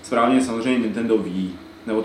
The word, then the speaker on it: Wii: Je, no jako jasné, Wii, dvě, je to jako my, že? – Jako my jenom.